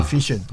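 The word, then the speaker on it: phishing: 不是 F。不是钓鱼 fishing, 发音一样叫 fishing,、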